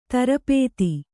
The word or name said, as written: ತರಪೇತಿ